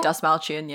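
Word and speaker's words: Dastmalchian: And when will, I'm going to butcher this, David, is it Dasmalchian? – Say Dasmalchian, yeah.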